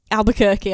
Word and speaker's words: Albuquerque: And Albuquerque.